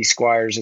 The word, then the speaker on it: Squyres: My name is spelled Squyres. So if you're looking up Andy Squyres, it's funky. It's Squyres.